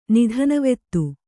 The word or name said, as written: ನಿಧನವೆತ್ತು